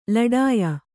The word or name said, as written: ಲಢಾಯಿ